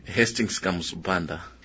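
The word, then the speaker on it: Hastings Kamuzu Banda: Hastings Kamutsu Banda.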